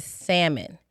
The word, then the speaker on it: salmon: So let's talk about the second food that is good for your body and delicious is salmon. Not salmon. – Say it again for the people in the back. – Salmon. The L is silent.